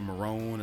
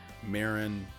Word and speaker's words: Marin: He calls her Marone instead of Marin.